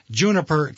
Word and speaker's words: Junapr: It's spelled differently, like you said. It's J U N A P R, and it's a play on my maiden name, which is Junas, yeah.